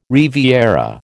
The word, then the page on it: riviera: American English